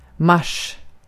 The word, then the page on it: maaliskuu: /ˈmɑː.lisˌkuː/